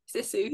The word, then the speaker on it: Sisu: Sisu, yeah.